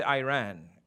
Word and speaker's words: Iran: Americans say Iran. It's Iran.